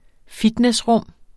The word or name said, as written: fitnessrum